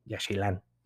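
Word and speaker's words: Yaxchilán: Y, eh, por ejemplo, este tema de que la X se pronuncia como no, Shkaret Tishkoko, eh, Yashilán, ¿no?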